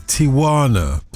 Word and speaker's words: Tijuana: And I've resisted trying to tell you, but it is Tijuana.